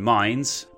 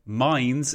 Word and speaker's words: Mainz: By the way, before we go any further, a huge thanks to my wonderful patron, David Lund, for letting me know that Mainz is not in fact Mainz. I apologise, I don't really know why I was pronouncing it as Mainz all this time. I mean, I can understand in the one sense why I would, but yes, I feel like Mainz makes a lot more sense.